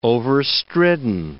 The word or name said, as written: overstridden